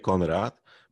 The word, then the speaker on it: Konrad: Nie, ja, ja to znam bardzo dobrze, bo moje imię Konrad, Brazylijczycy najczęściej mogą, niektórzy wymawiają jako Konradu, czyli to wersja ta portugalska tego imienia, ale w większości mówili Konradzi, czyli e, w ogóle zero e, podobieństwa, prawda? – Ja Bo...